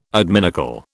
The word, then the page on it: adminicle: British English